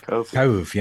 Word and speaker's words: Cobh: It's absolutely, it's a beautiful and, can I say, emotive tribute to Stan and Babe's visit to Cove. Is that how you say it? Cove? – Cove, yeah.